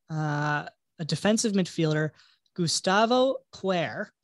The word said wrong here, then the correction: Cuellar: who's Colombian, plays for Al Hilal. I said that wrong, didn't I? – Cuellar. – Okay. – Gustavo Cuellar.